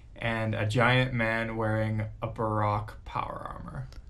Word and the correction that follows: Baroque: Am I saying that right, Brock? – Baroque? – Baroque, yeah.